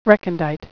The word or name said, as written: recondite